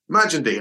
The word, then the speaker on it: Magendie: About 2 weeks ago, we were recording an intro, and hi, I'm Chris Hoy, and I'm Matt Magendie. And I was like, I sort of stopped and laughed at it, right, do it properly. He said, no, that is actually how you pronounce my name.